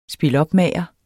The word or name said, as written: spilopmager